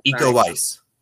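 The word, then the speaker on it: Iko Uwais: Eco Weiss. – Eco Weiss. Okay,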